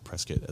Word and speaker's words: Prescott: Prescott, as the locals say.